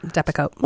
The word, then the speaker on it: Depakote: Am I saying that right? – It is. Yep, Depakote.